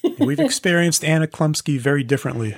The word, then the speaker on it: Chlumsky: And Anna Chumsley. Yeah. – Anna Chlumsky? – I guess I pronounced her name wrong. – Yeah. Anna Chlumsky.